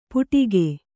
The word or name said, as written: ಪುಡಿಕೆ